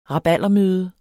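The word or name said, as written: rabaldermøde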